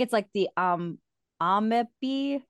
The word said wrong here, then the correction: Amabie: I'm so saying it wrong. It's Amabie, but it's basically says it's Japanese mer people with bird-like torsos and three legs and scales.